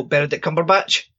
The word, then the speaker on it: Benedict Cumberbatch: I'm going to say his name properly, not to correct you, but just because I really like saying his name. So Benedict Cumberbatch.